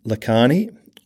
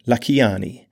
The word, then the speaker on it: Lakhiani: There's a big difference between Vishen Lakhani or Vishen Lakhiani. And if you pronounce it correctly, you get extra points.